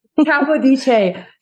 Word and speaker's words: Capodice: So I am bringing on Jillian Capodice. Is that how you say your last name, Jillian? Did I just totally botch that? You have to say it with an Italian flair. It's Capodice. – Capodice.